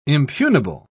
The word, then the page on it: impugnable: British English